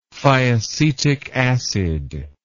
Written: thiacetic acid

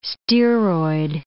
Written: steroid

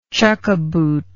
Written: chukka boot

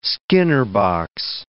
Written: Skinner box

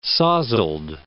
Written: sozzled

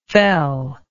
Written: fellness

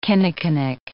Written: kinnikinnick

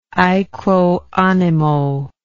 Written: aequo animo

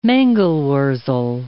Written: mangel-wurzel

